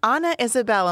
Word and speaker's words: Ana Isabelle: Hello, Ana Isabel.